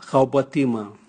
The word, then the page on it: gaobwatimwa: Raga